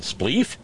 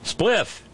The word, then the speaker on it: spliff: Spleef? Spliff! Rhymes with biff.